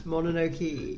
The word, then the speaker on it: Mononoke: Have I said it wrong again? – Yes. – Mononoke. – Yeah, that's it. – Okay.